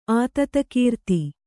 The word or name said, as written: ಆತತಕೀರ್ತಿ